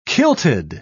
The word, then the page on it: kilted: British English